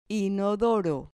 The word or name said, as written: inodoro